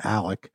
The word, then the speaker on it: Alex: It's Alec Murdoch is his name. That's not how he spells it. He spells it A-L-E-X, but everyone calls him Alec.